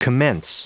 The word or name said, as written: commence